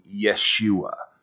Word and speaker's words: Yeshua: Um, if we put the Hebrew accent on it and pronounced it as it is written, then you you get the name Yeshua which is Yahweh's salvation.